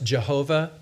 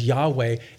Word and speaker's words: Yahweh: It's the four consonants in Hebrew referred to as the tetragrammaton, YHWH, pronounced Jehovah, pronounced probably more accurately as Yahweh, and it simply means the existing one.